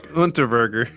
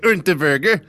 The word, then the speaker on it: Unterweger: Jack Unterweger. Whoa, I just had to say it like that. – Not even gonna try it. – Yeah, that's how I have to say it, German style.